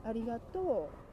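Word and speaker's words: Arigato (ありがとう): Arigato. So the ending is like... – Yes. Arigato. Ending is down, okay.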